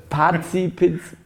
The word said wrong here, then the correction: partizipativste: Sagst du bitte. Das partizipativste Format wolltest du sagen.